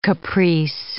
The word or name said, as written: caprice